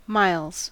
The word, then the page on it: miles: American English